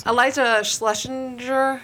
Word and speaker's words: Eliza Schlesinger: Eliza Schlesinger. – Yep. – Is that how you say her name? – That is it.